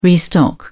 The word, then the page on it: restock: British English